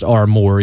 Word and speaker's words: Armour: I believe it's pronounced Armour, even though it looks like armor with a U in it.